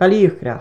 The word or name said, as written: BALEACHDRACH